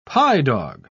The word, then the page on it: pye-dog: British English